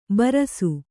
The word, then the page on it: ಬರಸು: Kannada